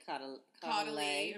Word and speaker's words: Caudalie: I keep saying Cloutalie. How are you pronouncing it? – I say Caudalie. – Or maybe it's not even an L in there. Whatever. – No, it's an L.